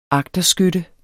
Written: agterskytte

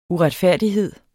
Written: uretfærdighed

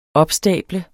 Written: opstable

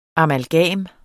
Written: amalgam

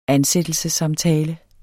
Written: ansættelsessamtale

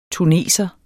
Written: tuneser

